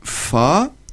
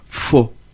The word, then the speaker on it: fa: Alors, le fa se prononce fa, comment ça se fait, mais ça peut s'entendre fo.